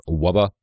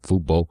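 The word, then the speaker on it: Wubbo: It's W-U-B-B-O, like Wubba, but I'm sure it's pronounced like Vubo.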